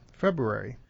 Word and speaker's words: February: To actually break it February is really cumbersome. So that's why it gets dropped in speech. I tend to somehow slip a little thought of an R in there myself. Maybe just so I won't forget to do it when I'm spelling it out. February.